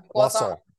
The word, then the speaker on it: croissant: Yeah, croissant. Croissant. That's pretty good. That's how you say it.